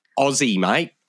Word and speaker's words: Aussie: Step one is don't pronounce it Aussie. Aussie. – How do you pronounce it? – Aussie, mate. Aussie. – Like Ozzy Osbourne.